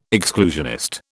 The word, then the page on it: exclusionist: British English